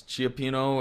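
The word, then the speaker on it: cioppino: I think Chipino, yeah. So there's plenty of debate on whether it's Chiapino or Chipino. I'm going to go with Chipino. – I'm going to go with Chipino.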